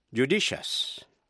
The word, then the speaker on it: judicious: Nếu trước đuôi IOUS có âm T, C hay X. Thí dụ, judicious, judicious. J-U-D-I-C-I-O-U-S.